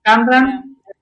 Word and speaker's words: Kamran: Me encantó, por ejemplo, la escena cuando ella conoce a Karam, ¿cómo se pronuncia? Kamran.